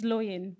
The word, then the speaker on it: Loyin: So my name is Loyin, which is a name of Nigerian origin. And people often say to me, knowing how to spell my name helps with the pronunciation. So my name is spelled L-O-Y-I-N.